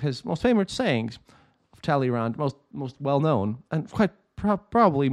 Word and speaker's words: Talleyrand: Well, I have some other words to say to put in his government, and I asked people how to pronounce this properly by the words of immortal Talleyrand, or Talleyrand. I try to make it kind of sound properly French because this needs to be said in French.